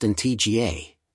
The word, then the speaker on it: Antigua: Antigua, pronounced in TGA, and Barbuda, Barbuda, is located in the heart of the Caribbean Sea.